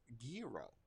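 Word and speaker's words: gyro: And that's pronounced Giro.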